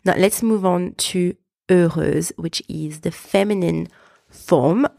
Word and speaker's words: heureuse: Instead of the silent X, we have S-E pronounced Z, Z, heureuse.